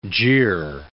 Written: jeer

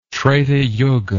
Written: Treta Yuga

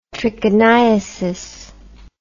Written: trichiniasis

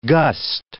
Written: gust

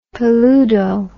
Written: peludo

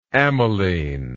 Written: amylene

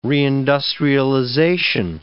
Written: reindustrialization

